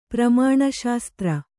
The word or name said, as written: ಪ್ರಮಾಣಶಾಸ್ತ್ರ